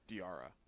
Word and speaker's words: Diarra: R-R-A. – That's just opening up teasing for five-year-olds. That's tough. – Diarra.